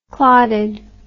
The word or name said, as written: clottedness